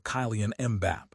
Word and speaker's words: Kylian Mbappé: It is easy to get caught up in the reputation of players like Harry Kane or Kylian Mbappé, pronounced K-E-E-L-A-N M-B-A-P-P-E. But relying on those names is a liability right now.